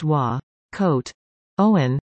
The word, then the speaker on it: Wakotauan: Wakotauan, pronounced Wa, Coat, Owen, is a Cree word that means kinship and connectedness, and recognizes the complexity and interconnectedness of the people, animals, lands, air, and waters.